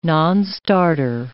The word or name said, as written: nonstarter